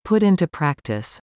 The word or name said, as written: Appealing